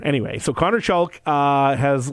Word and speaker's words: Chalk: U of R's Connor Chalk. Am I pronouncing his name right? Yep. Is that right, Chalk? Okay, I was going to say I've said it enough times.